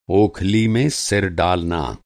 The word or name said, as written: ओखली